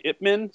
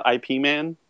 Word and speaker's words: Ip Man: Did we say Ip Man? So is it it's not IP Man? – It's Ip Man, but yeah, I you can call him IP Man. That's how it's spelled.